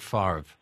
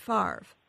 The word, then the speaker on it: Favre: But they have a little thing in there about Brett Favre, and they say it may be pronounced V-R-E, but... He pronounces it as if it was R-V-E, and we always take the pronunciation used by the person, not by how it's spelled.